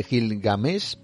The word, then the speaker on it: Gilgamesh: De Gilmades de Gilgamesh, perdón, lo he dicho bien,